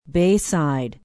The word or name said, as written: bayside